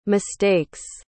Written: mistakes

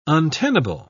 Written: untenable

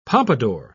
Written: pompadour

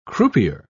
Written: croupier

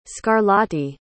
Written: scarlatti

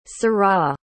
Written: syrah

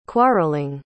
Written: quarreling

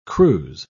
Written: cruse

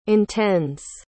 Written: intends

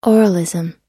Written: oralism

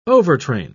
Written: overtrain

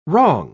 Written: wrong

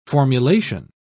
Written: formulation

